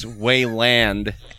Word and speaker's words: Wayland: Anyone going to correct my pronunciation on the Wayland? – It's Wayland.